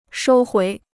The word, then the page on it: 收回: shōu huí